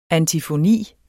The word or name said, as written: antifoni